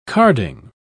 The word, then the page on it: carding: British English